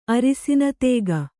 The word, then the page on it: ಅರಸಿನ ತೇಗೆ: arasina tēge